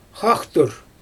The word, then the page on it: xaxtur: Xyzyl